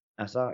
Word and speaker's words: Nassau: So we're going to go stay in Nassau. Is that how you say it? – Nassau, yeah.